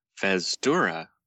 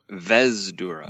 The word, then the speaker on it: Vesdura: Fez-dura. – Fez-dura. – Fez-dura. – Maybe I pronounced that wrong. Vez-dura. – Oh, Vez. – Vez with a V. – V-E-S-D-U-R-A.